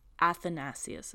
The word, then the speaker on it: Athanasius: In 1665, I don't know how to say this guy's name, Athanasius is how you say it, Athanasius Kircher.